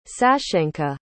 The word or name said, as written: sashenka